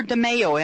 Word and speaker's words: DeMaio: Is it DeMaio or DeMaio? Am I pronouncing it right? – Good morning, Megan. Thanks for having me on your show. It's DeMaio. – DeMaio? – That's close enough. – Okay, yes, like tomato, right?